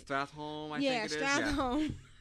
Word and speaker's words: Stratholme: I was turning in one of the Stralathom... I can never say that instance, right? – Stratholme. – I always want to put an extra syllable in there.